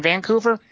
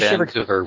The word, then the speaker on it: Vancouver: I love how you say Vancouver with emphasis on Van. – Vancouver.